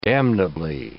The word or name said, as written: damnably